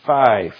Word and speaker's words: fie: Having made all sure, they proceeded to the cardinal's chamber, who, perceiving they had a design upon his life, exclaimed, Fie, fie. I think that's how you pronounce it, F-I-E, F-I-E.